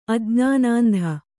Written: ಅಜ್ಞಾನಾಂಧ

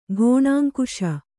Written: ಘೋಣಾಂಕುಶ

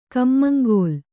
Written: ಕಮ್ಮಂಗೋಲ

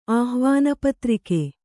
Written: ಆಹ್ವಾನಪತ್ರಿಕೆ

